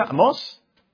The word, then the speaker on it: Amós: El libro de Amós, así se pronuncia, Amós, acento en la O.